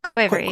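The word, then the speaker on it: quavery (ქვევრი): And it's called a quivery. Did I pronounce that correctly? – Yeah, quavery. Yeah, exactly.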